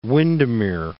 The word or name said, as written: Windermere